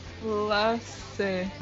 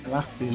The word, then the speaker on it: Lacê: Como é mesmo a pronúncia do sobrenome dela? – Ô, Jucimara. – Lacê. – Que é em Glacê, né? – Como Glacê, é. – Lacê, Jucimara Lacê.